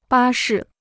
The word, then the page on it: 巴士: bā shì